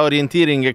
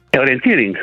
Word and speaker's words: orienteering: Dici l'accento per prima cosa, come si dice? – Orienteering.